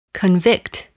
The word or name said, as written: convict